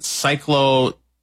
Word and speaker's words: cyclosporiasis: Is it cyclosporiasis? – Cyclosporiasis. – Yes, sporiasis. There you go. – That is how I believe it is pronounced.